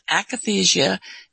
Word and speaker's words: akathisia: Well, I see you speak here about, I don't know how you say it, akathisia? – Yes. – Yeah, and akathisia.